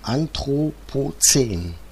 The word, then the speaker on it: Anthropozän: Das neue Album heißt ja Störsender Anthropozän.